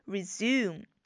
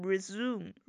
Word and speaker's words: resume: 注意这个单词的发音，在英音中呢是 resume, 而在美音中呢这个小耶就不是太明显，就是你是可以不把它发出来的，大部分美国人都会把它读成 resume, 就是一个长音呜。